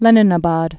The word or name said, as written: Leninabad